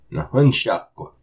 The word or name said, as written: Nahunsháq!